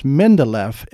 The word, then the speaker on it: Mindeleff: It's Mindeleff, M-I-N-D-E-L-E-F-F, Cavates. So it's cave with A-T-E-S at the end of it.